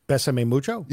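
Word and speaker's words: Bésame Mucho: Besame Mucho.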